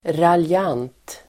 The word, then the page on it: raljant: [ralj'an:t]